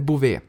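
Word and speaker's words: Bouvet: Uttalas det Bové? Det är Bové.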